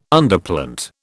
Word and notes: Underplant: British English

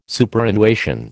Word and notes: Superannuation: American English

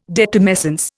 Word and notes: detumescence: Indian

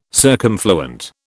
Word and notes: circumfluent: British English

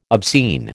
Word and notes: obscene: American English

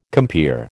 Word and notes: compeer: American English

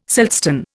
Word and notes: siltstone: Indian

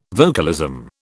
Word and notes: vocalism: British English

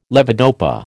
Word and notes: levodopa: American English

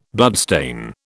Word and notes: bloodstain: British English

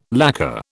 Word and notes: lacquer: British English

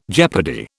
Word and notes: jeopardy: British English

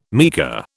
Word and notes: meagre: British English

